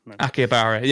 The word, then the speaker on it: Akihabara: Yeah, everyone's going to tell me off for pronouncing that incorrectly. – Akihabara. – Yeah, that's it. It is Akihabara.